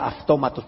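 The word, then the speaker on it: aftómatos (αὐτόματος): Entonces, cuando la transliteras al castellano, si la escribes, se la escribiría, o al inglés, se escribiría como automatos. Pero se pronuncia aftómatos, pero es irrelevante cómo se pronuncie.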